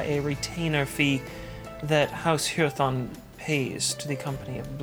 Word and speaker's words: Hyrthon: Hurathon. – It's spelled H-U... – H-Y-R-T-H-O-N.